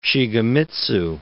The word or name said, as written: Shigemitsu